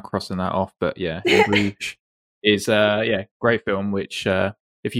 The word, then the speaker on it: In Bruges: And then, and I'm going to say this wrong, so please correct me, but In Bruges? – In Bruges is correct, yeah.